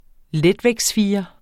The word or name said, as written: letvægtsfirer